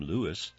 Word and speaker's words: Louis: With his infectious smile and raspy voice, Louis Armstrong, who actually pronounced his own name Louis, won over fans worldwide.